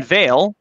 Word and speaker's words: Vail: And Vail, which is VAIL, which the pronouns are V, Ver, Vem.